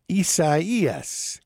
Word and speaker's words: Isaias: Isaias is how it's pronounced, which is kind of I don't know anybody named Isaias.